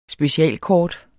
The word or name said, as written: specialkort